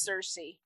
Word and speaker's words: Circe: It is pronounced Circe.